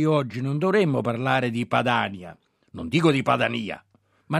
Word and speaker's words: Padania: Quindi anche Padania, nella nostra pronuncia, si pronuncia correttamente Padania e non Padania,